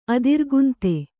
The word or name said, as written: ಅದಿರುಗಂತಿ